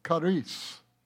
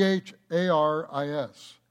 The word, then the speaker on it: charis: Now the English pronunciation is charis, C H A R I S. It's actually pronounced haris like H.A. Reese.